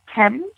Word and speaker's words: Themes: It's pronounced Thames, the Dublin Thames boot, but it's spelled T-H-E-M-E-S.